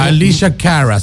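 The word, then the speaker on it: Alessia Cara: Alyssa Carey. Cara, ¿está bien pronunciado? Tú, corrígeme. Alicia Cara, sí.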